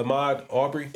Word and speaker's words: Ahmaud Arbery: Am I saying his name right? Uh, Ahmaud Arbery. Yep.